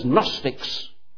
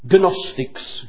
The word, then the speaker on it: Gnostics: People who are known as Gnostics. Gnostics, if you want to pronounce it as they would up north. The Gnostics, the know-alls, they said they knew, they know.